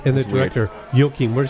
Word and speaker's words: Joachim: Joachim. – Joachim? – Yes, Joachim Trier.